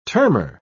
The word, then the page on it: termer: British English